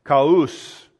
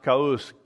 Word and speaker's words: Khus: Cush, also in Chaldee, is K-H-U-S. It's Kaus, pronounced Kaus, chaos.